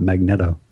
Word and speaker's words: Magneto: Which should have made me realize, however many years ago I read this, that it's pronounced Magneto and not Magneto.